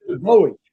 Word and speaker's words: Bowie: I'd say it, and I'm like, no, it's Bowie, it's Bowie. But did you know that David Bowie, the singer, he took that name from Bowie? – Oh.